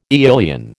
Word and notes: aeolian: American English

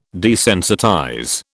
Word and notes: desensitize: British English

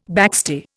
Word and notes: backstay: Indian

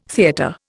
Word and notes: theatre: Indian

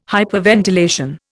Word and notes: hyperventilation: Indian

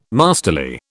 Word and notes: masterly: British English

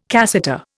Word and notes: cassata: Indian

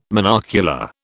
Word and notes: monocular: British English

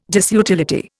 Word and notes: disutility: Indian

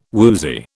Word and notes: woozy: British English

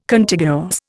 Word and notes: Contiguous: Indian

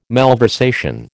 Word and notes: malversation: American English

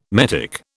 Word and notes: metic: British English

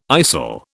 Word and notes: istle: British English

